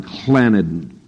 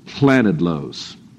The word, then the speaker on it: Llanidloes: And in a little town called, now they have these towns that have double L's on the front of them. And you're supposed to kind of gargle and spit at the same time somehow to say them. But it's something like Llanidloes, Llanidloes.